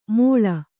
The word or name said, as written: ಮೋಳ